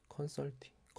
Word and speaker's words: 컨설팅: 그래서 아까도 조금 쭉 얘기했지만 저는 이런 컨설터 컨설턴팅 그 맞나요? 발음이 컨설턴팅 컨설팅 컨설팅 어, 컨설팅 예.